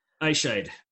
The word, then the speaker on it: Eyeshide: If you're ever really wondering how to pronounce his last name, it's like Eyeshide the bed. – Eyeshide.